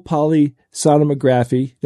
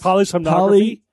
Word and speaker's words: polysomnography: So they did something called a full polysomnography. Did I say that right, guys? – Say that again. – Almost. – Polysomnography.